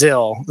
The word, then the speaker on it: Zill: My last name is Zill, Z-I-L-L.